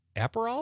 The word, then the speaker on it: Aperol: Liz from Sun Prairie says she's going to have tequila and soda with a lime or a splash of Aperol.